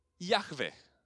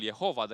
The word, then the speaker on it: Iahve: Și anume, faptul că în Vechiul Testament um, revelația de sine a lui Dumnezeu este sub numele de Iahve. Uh, se, există popularul Jehova, dar se pare că este o, o pronunțare uh, eronată a celor patru litere Y, H, W, H.